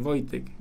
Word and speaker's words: Voitec: Es que no, no, es no Voltec, es Voitec. – Voitec. Con I. Ah, ah horrible, horrible.